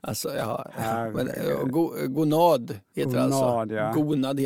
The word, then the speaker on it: gonad: I avsnitt 50 gör Anders en ny amygdala när han säger gonad. – Mm. – Betoningen ska vara på A. Gonad.